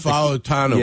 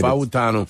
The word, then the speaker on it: Fautanu: Is it Fontenua? – Troy Fautanu. – Yeah, from Washington, right? – Fautanu. – Fautanu? – Yep.